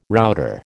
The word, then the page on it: router: American English